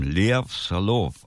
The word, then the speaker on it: Soloff: Tatsächlich war es damals schon deren zweite und die erst hatte David Clayton Thomas als Sänger und den Trompeter Lou Soloff, der eigentlich mit seinem russischen Namen Lev Solov ausgesprochen werden müsste, macht aber keiner.